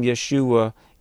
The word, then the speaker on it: Yeshua: The actual, you know, pronunciation of, well, the first letter in the name Yeshua is the letter Yod, which represents the Y sound in Hebrew.